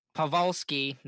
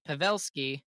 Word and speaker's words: Pavelski: I almost you keep telling me I'm going to say it wrong, and I still say it wrong. Pavelski, no, no. – Pavelski and we could have been down three to one in that series.